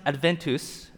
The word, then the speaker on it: Adventus: So the word Advent comes from the Latin word right here. It's pronounced Adventus, okay?